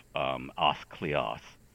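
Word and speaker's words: Áth Cliath: And before everybody who speaks Gaelic jumps down my throat about the pronunciation, that, as far as we know, is the most accurate pronunciation for the time. Gaelic has had a great vowel shift like everything else. And so when somebody who is a modern speaker says, oh, that should be Ath Cliath or whatever, you go, yeah, well, okay, in 1735 it would have been Ath Cliath.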